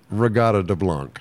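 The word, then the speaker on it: Reggatta de Blanc: Regatta de Blanc.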